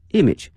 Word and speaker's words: image: image 啊，我们讲过这个词 image，但是同学们注意啊，这个词的发音哈、它的重音放到前面哈、 image，raise image，那么前面可以加一些形容词，比如说国际形象，to raise its international image。